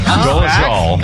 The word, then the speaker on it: Dolezal: How do you say her name? – Oh, yeah. – Dolezal.